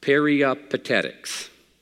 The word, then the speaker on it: peripatetic: Aristotle's followers were known as, listen close, peripatetics. I would not be a good Baptist preacher if I didn't say that sound that rhymes with very pathetic. Peripatetic. Very pathetic.